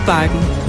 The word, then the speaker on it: Bakken: Bakken? Zo wordt het dus blijkbaar uitgesproken in het Deens.